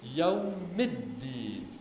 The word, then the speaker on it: الدین: ببینید یوم الدین، مالک یوم الدین، در واقع دو تا دال اینجا تلفظ میشه، نه مالک یوم الدین. مالک یوم الدین که اصلا دال مشدد گفته میشه و دال از نظر تلفظم میبا همیشه پر بری گفته بشه، میبا در واقع ظاهر بشه، مالک یوم الدین، یوم الدین. پس این تشدید دالم ها.